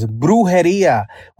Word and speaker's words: Brujeria: You named one out of Pico Rivera, but I think you said the name wrong, homie. The name of that brewery is Brujeria, which in English means witchcraft.